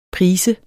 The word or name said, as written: prise